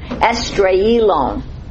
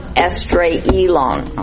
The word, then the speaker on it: Esdraelon: Esdraelon. Okay, it's in your handout. It's the E word, Esdraelon, Esdraelon.